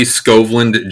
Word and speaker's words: Skovlund: So my name is Marty Skovlund, Jr., I grew up in South Dakota.